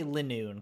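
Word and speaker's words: Linoone: I always say Linoon because it's L-I-N-O-O-N-E.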